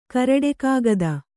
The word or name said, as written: ಕರಡೆಕಾಗದ